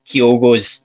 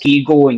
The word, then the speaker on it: Kyogo: But I'm talking about people that can't even pronounce Kyogo properly. I've heard them getting called Kigo and Kaigo, and you know, it's just absolutely like, what?